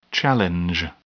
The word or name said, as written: challenge